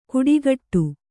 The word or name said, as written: ಕುಡಿಗಟ್ಟು